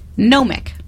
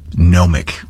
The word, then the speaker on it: gnomic: Gnomic. – Gnomic. – Gnomic. – Like with a silent G or with no silent G? – Yes, good for you. Silent G.